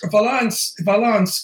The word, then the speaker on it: Vilanch: V-I-L-A-N-C-H. – Yeah, Vilanch. – No. – Vilanch. I'll go fuck yourself. – How do you spell, say chess? It's not cess, it's chess. You have to pronounce the C-H.